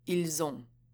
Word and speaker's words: Ils ont: For example, while the S in il is generally silent, when it comes before a vowel, it will be pronounced as a Z. Ils ont.